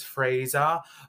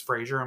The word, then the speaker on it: Fraser: He has a British accent, so he says Fraser, but it's Fraser, I'm going to say, because it makes me have a lisp when I try to pronounce it with its accent.